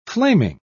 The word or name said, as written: flaming